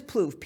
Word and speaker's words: Plouffe: Okay, I'll tie you back to someone did send me what Foof had to say on Twitter, and I'm probably not saying his name correctly, but Plouffe, I'm sorry, it is Plouffe, P-L-O-U-F-F-E, on Twitter about Trump.